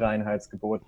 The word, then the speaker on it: Reinheitsgebot: The Bavarians have their own Reinheitsgebot because they are there special again in Germany.